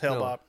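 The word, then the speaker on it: Hale-Bopp: It's Hail Bop, yeah.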